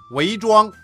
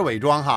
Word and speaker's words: 韦庄: 作者也很厉害呀，韦庄不是伪装哈，不过很多人都把它念成伪装。